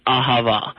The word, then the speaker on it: Ahava: It's correctly pronounced Ahava. It is Israeli, you know, they're Israeli Dead Sea Cosmetics. They're sold in a variety of stores all around you. The spelling is A-H-A-V-A.